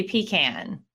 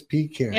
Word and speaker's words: pecan: Because I'm from North Carolina, and in North Carolina, we say pecan. – Yeah, that's what I heard you say. And I thought, I have heard that, but I thought, oh, they're mispronouncing it. But if Lara Trump says it, it's pecan. – It must be pecan.